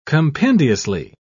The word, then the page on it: compendiously: British English